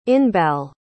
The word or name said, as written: inbal